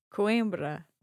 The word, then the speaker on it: Coimbra: So we have a tricky sound here in Coimbra, right? Coim. – Ah. – A bit nasal. Coim. – Coim. – Coimbra. – Coimbra.